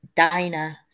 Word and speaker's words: Dinah: She lived about 1850 BC, and her name is Dinah. So it's pronounced Di as in Die and then Nah, and it means justice.